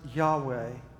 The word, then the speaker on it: Yahweh: The name is spelled with four Hebrew letters and is usually pronounced Yahweh, though ancient Israelites as well as modern Jews consider the name so sacred that they do not speak it aloud.